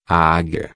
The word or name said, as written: aguia